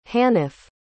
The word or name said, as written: hanif